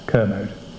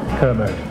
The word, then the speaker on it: Kermode: and I was very conscious that Mark's surname is pronounced Kermode. I knew that because I'd looked it up before, and I knew that you have to think of Kermit. Kermit, Kermode.